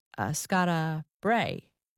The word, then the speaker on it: Skara Brae: seeing the Ring of Brodka and also Skara Brae.